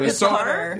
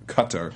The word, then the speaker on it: Qatar: No, it's not guitar. – Some people say guitar, but they actually said that's very far removed from being the right way to say it. So it's Qatar.